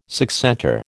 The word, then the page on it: succentor: American English